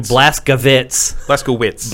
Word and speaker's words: Blazkowicz: Wolfenstein Youngblood features BJ Blazkowicz. Is that the right way to say it? – Blazkowicz. I mean, it probably originally was supposed to be Vitz, but he's American now.